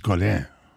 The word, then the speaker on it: Godin: And in fairness, it should be pronounced Godin.